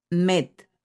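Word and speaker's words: met (מת): Si quitas la primera letra de Aleph y dejas la del medio y la última, la palabra verdad se convierte en la palabra muerte, muerto o muerte. Se pronuncia met.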